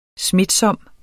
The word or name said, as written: smitsom